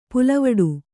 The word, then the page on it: ಪುಲ್ವಟ್ಟೆ: pulvaṭṭe